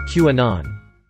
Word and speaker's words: QAnon: QAnon.